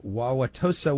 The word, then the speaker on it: Wauwatosa: Wauwatosa, Wisconsin. Go ahead with your question. – Very good on pronouncing Wauwatosa.